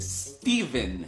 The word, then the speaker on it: Stephen: It is S-T-E-P-H-E-N. – Ah, okay, S-T-E-P-H-E-N. So, Stefan. – No, it's Steven. – Ben là, it is not a V, it is a P-H, Chris. You are clearly mentally confused. It is Stefan. Or maybe I can toleration Stephen. – Well, I clear-a-mentally know how to pronounce my own name. It's Stephen.